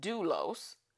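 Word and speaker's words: doulos (δοῦλος): And so slave is doulos, and it's spelled phonetically D-O-O-L-O-S, if you ever want to know the phonetic spelling of it.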